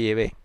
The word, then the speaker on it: Yahvé: y he puesto simplemente el nombre Yahvé, que es la pronunciación más probable, más correcta del nombre del Dios de Israel.